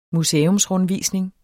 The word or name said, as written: museumsrundvisning